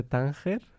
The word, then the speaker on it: Tánger: Am I pronouncing it correctly? – More or less, Recuerdos de Tánger. – Tánger, yes. – But pretty good.